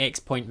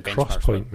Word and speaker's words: XPoint: Optane is Intel's sort of next generation SSDs, use 3D XPoint memory. They released some consumer versions that were designed as caches for hard disk drives earlier in the year. The cross-point memory, not X-point. That's how it's pronounced.